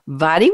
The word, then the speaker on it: Vadhi: Vadhi, would you please introduce yourself and would you tell us what this topic means to you?